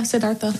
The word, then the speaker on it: Siddhartha: I was thinking about when they talked about Buddhism, and they said that when Siddhartha, is that how you say it? – Yeah, Siddhartha. – Okay, Siddhartha.